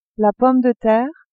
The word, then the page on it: pomme de terre: female